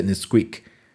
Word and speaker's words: NSQK: También hay uno que se llama Nesquik, así, pero se escribe NSK, NSQK y se pronuncia Nesquik.